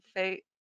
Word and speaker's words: Faye: I did not know who Tammy Faye is. It did I pronounce her last name right? Faye? – Yes, you did.